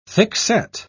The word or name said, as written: thickset